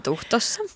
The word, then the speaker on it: Tuhtossa: Työskentelee Tampereella ravintola Tuhtossa, niinkö se lausutaan Tuhtossa. – Ihan Tuhtossa.